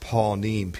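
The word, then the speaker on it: panim (פָּנִים): It's spelled P-A-N-I-Y-M, but it's pronounced panim, P-A-W-N-E-E-M. Before is panim.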